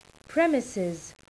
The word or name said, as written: premises